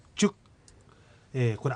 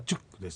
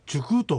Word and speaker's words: チュック: それに対してチュック、これ秋ということですが、発音はチュックです。チュックと言うんだよと言っています。それに対してチュクと間違った発音しています。ですからチュクソモネ、チュクじゃないよと、チュクと正しい発音を、示しています。